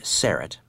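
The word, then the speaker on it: Syrett: I'll also direct you to my website, which is Richard Serrett, and let me spell that last name for you if you're new to the program.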